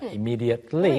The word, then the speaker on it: immediately: Immediately. – Really? – 다시 한번. – Immediately. – 네, 원래 이제 T 발음도 마지막에 해주는 게 맞는데 잘안 해요.